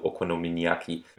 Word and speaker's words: Okonomiyaki: Also Okonomiyaki.